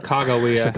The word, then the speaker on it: Sacagawea: Ooh, or Saka. How do you say it? – Sacagawea.